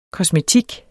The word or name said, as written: KOSMETIKKEN